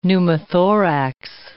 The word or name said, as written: pneumothorax